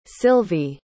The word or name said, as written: Sylvie